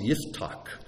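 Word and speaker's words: Yiftach (יפתח): In Hebrew, his name is pronounced Yiftach, which sounds kind of odd, but we'll just stick with Jephthah this morning.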